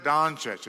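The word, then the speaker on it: Dončić: The name Luka Doncic is how you say his last name.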